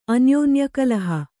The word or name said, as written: ಅನ್ಯೋನ್ಯಕಲಹ